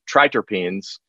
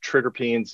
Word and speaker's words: triterpenes: And one of those ways was we added a really potent extract from olives called triterpenes. – Oh, let's say that again, because that sounded so sexy. Come on. – Triterpenes. And depending on where, sometimes pronounced triterpenes as well.